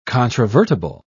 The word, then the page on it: controvertible: British English